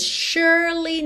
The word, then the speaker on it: surely: Atenção aí que esse S tem um som de SH, ok? Ou X, como você preferir pensar. E o E do meio não é pronunciado. Então, surely.